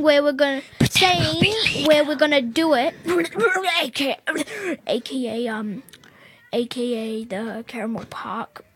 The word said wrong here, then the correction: Campbell Park: Campbell Park. – I still don't know how to pronounce it. I'm a newie. – Campbell Park. – I'm just gonna call it Campbell. – He meant to say Campbell Park.